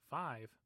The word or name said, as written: saton